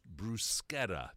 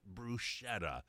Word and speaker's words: bruschetta: And you even point out, and I appreciate the fact, that it's pronounced bruschetta, not bruschetta.